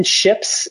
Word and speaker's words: Shjips: They're called Wooden Ships, and Ships is spelled SHJIPS.